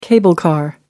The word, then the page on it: cable car: American English